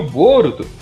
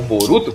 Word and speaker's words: Boruto: E o Boruto, ou Boruto, como vocês preferirem, pode usar a pronúncia brasileira ou japonesa aí. Lembrando que em japonês a última sílaba é sempre tônica aí pros curtidores. O.